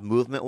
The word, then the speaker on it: MVMT: It's pronounced movement.